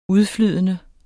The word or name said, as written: udflydende